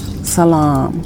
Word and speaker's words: Salam: And the emphasis is on the last syllable, lam, salam.